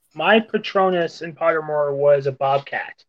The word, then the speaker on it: Patronus: Patronus.